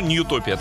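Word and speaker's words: Nutopia: Ньютопия, Нутопия. Ну, Ньютопия так все-таки по-английски, наверное, правильно читается.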